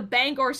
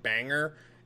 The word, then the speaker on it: Bangor: I don't know whether they just wanted to name drop it or because the proper pronunciation is Bangor. They did it pretty well. Like people that don't know call it banger and that's completely wrong.